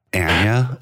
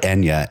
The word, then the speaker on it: Anya: I believe it is Anya. – Oh, I love that. Anya. Okay. And, I've been saying it because it's like Enya. Enya Taylor-Joy.